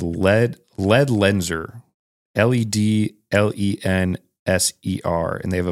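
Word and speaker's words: Ledlenser: LED Lenser. So it's LED LENSER.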